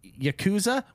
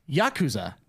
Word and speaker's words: Yakuza: Like Yakuza, which is funny. Here's a pronunciation thing. I think it's Yakuza. – Yakuza. – Okay, yeah, we've always been saying Yakuza.